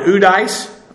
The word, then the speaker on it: oudeis: For no one, udeis, O-U-D-E-I-S. Udeis literally is a compound from the negative u and the numeral heis, one. But they would not care for uheis in the Greek, so they phonetically the d comes in there.